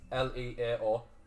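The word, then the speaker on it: Leão: It's like Lau. L E A O.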